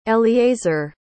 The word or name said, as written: eliezer